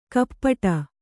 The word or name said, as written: ಕಪಾಟೆ